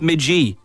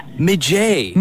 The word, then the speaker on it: midge: So you are saying it is spelled M-I-D-G-E, but pronounced midgy? – In Scotland, yeah. – Midgey.